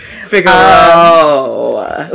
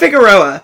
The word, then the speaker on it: Figueroa: Figueroa. You have to say it like that. Not Figueroa. Figueroa.